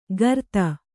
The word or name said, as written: ಗರ್ತ